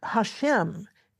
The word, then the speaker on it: Hashem: And furthermore, in discussions amongst pious Orthodox Jews, the Tetragrammaton is replaced by a generic word simply meaning the name, pronounced Hashem in Hebrew.